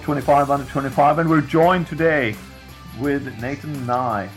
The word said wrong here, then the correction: Nathan Ni: Nee, sorry, I asked you like 2 seconds ago, Nathan. I'm sorry for the mispronunciation of your name.